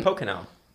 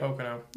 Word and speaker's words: Pocono: Pocono, right? – Pocono. – Yeah, that one.